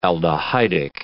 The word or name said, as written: aldehydic